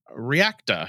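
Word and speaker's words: Reaqta: IBM Observability by Instana, Maximo Application Suite, Security, Reacta. I think it's how that's supposed to be pronounced because it's R-E-A-Q-T-A.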